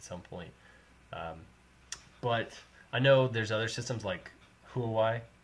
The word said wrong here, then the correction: Huawei: or rather Huawei, that's how you pronounce it, kind of the Chinese manufacturer.